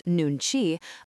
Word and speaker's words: nunchi: The Korean concept of nunchi, pronounced nunchi, offers another tool: understanding what others think and feel without direct questioning.